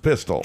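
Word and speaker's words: Pistole: Now it's spelled with an E on the end, but it's pronounced Pistol.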